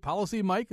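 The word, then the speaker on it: PolicyMic: Is it Policy Mike? Is that how you say it? – Yep, that's how you say it.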